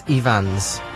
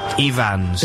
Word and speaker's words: Ifans: Also, it's Rhys Ifans, not Ifans. – Ifans. – Is it? – Yeah. – Is it, even though it's spelled... – In Welsh, one F is a V.